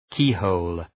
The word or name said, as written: keyhole